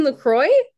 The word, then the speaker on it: La Croix: And she was like, I think you're saying that wrong. – She was like, oh, you mean La Croix? And I was like, is that how you say it?